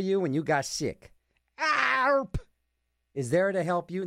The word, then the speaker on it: ARP: A-R-P. ARP. That's how it's pronounced.